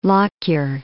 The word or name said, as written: Lockyer